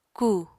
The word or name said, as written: ku